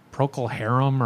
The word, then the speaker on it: Procol Harum: My dad would listen to like Van Morrison and Grateful Dead and like Procol Harum, or how is that pronounced? – Oh. You got it.